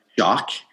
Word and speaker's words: Xoc: So I found the Mayan word is XOC, pronounced shock.